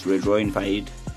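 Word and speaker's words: Rédoine Faïd: So if you say it like how the French people say it, it's Redroin Fade. Yes, Fade. Redroin Fade.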